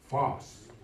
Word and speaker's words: phos: Phos. P-H-O-S. Pronounced phos. And that means to shine or to make manifest.